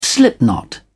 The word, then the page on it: slipknot: British English